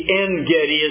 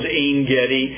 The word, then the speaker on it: En-Gedi: Title slide this evening is, as you can see, En-Gedi is the way we usually pronounce it. If you're in Israel itself, they usually add the I in the middle. It becomes En-Gedi.